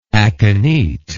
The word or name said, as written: akinete